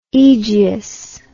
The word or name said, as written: aegeus